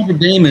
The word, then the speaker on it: Notre Dame: Well, Notre Dame is the university in Indiana. The cathedral in Paris is Notre Dame.